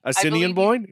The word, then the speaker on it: Assiniboine: Assiniboine? Is that how you pronounce it? I believe you correctly said that.